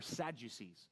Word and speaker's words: Sadducees: Okay, there were some important Pharisees, but most of the people who were in power were Sadducees. Now, you pronounce that sad, you see, because they were sad, you see, because their theology was bad.